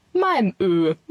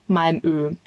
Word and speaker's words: Malmö: Und dann haben wir einen Tagesausflug nach Schweden gemacht, nämlich nach Malmö, wie es wirklich ausgesprochen wird. Wir haben immer natürlich Malmö gesagt, ist so schön eingedeutscht, aber es heißt theoretisch Malmö.